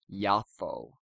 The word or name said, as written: Japho